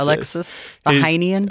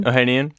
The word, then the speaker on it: Ohanian: Alexis Ohanian. – Ohanian.